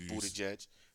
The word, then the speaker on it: Buttigieg: It's Buttigieg. Buttigieg.